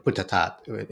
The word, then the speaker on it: Buddhadasa: Buddhadasa, or Buddhatat in I guess would be the more proper Thai pronunciation.